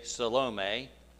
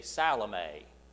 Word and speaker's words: Salome: And in the old King James Version, you would say Salome, but in the newer pronunciation, you say Salome.